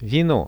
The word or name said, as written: vino